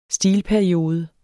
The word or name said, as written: stilperiode